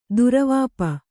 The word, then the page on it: ದುರವಾಪ: Kannada